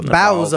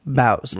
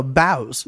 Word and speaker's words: boughs: The bowels of holly. – The what? – B-O-U-G-H-S. Bows. – Really? – Yeah. – So it is bowels. – No, bows. – Bows. Okay. – It's pronounced bows.